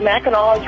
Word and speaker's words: Mackinac: Yeah, and it, it, people pronounce it Mackinac too, but Mackinac is really what Mackinac. it's called. Mackinac.